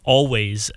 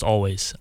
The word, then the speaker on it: Alvvays: I'm going to start off with this band, Always. It's Alvvays. They have a lot of popular songs, but I think they're pronounced Always. I'm pretty sure it's just spelled with two V's.